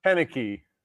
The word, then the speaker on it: Koenecke: Yeah, it's pronounced Koenecke.